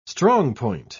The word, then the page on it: strongpoint: British English